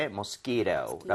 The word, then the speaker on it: mosquito: So, 모기를 얘기를 할 때는 이렇게 mosquito라고 스키로. – 얘기를 하고요. 뒤에 T라고 하는 부분은 모음 사이에 T가 있으면, 플랩이라고 해서 리을 비슷하게 발음이 됩니다.